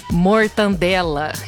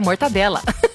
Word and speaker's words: mortadela: Outra palavra que muita gente fala errado, mortandela. É até mais gostoso falar mortandela, né? Mas, na verdade, é mortadela.